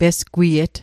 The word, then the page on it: pesgwiet: Mi'gmaq